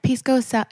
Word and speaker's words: pisco sour: They have a pisco sour. Am I saying it right? – Pisco sour. Yeah, pisco sour.